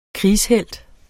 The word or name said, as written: krigshelt